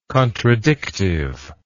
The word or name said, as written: contradictive